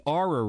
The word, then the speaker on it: Oura: The NBA is considering having its players use the, and we were wondering how you pronounce this. It's spelled, O-U-R-A. – Yeah, Aura. – We're just going to say Aura. – It's Aura, that's right. – Aura, okay.